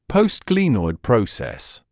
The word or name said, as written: postglenoid process